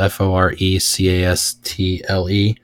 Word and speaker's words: forecastle: but the word it's usually spelled forecastle, F O R E C A S T L E, but pronounced forecastle.